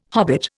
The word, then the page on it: hobbit: Indian